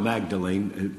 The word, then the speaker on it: Magdalen: It's spelled Magdalene, pronounced Magdalen Chapel,